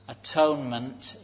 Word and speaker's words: atonement: Atonement, in this case, being pronounced at one-ment.